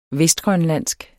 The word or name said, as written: vestgrønlandsk